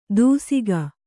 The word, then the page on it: ದೂಸಿಗ: dūsiga; Kannada